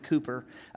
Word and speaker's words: Cowper: In 1774, a man named William Cowper, it's spelled C-O-W-P-R, but it's British, so they pronounce it Cooper.